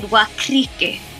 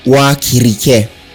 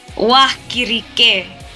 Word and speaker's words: Wakirike: but you said Wakrike. – Close enough. – How do you say it? How do you say that term? – Wakirike. – Wakirike.